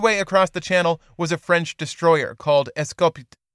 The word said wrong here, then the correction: Escopette: Ah, the French. I just don't know their language. I finally got Wooster right, though. Does somebody want to give me a little credit for Wooster? Pronunciation. It's just so embarrassing. – Escopette. – Escopette.